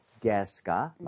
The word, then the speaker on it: guess: 여기서는 어떻게 보면 you가 묵음이라고 생각을 해야 돼요. 그래서 구에 이렇게 가지가 않죠. 무조건 guess가 맞는 발음이고요.